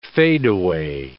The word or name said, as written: fadeaway